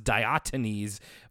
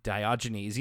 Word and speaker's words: Diogenes: I thought it was Diotanes, but it is definitely Diogenes.